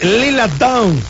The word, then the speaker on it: Lila Downs: Es Lila Downs. – Lila Downs.